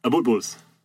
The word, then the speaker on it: Abutbuls: Sorry, Abutbuls, am I saying that right? – Abutbuls. – Abutbuls.